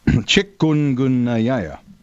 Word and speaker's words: chikungunya: You know, I actually learned how to pronounce that this week, Joe. – Fire away. – Chikungunya. – Chik. – So you did it pretty damn well.